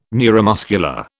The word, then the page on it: neuromuscular: British English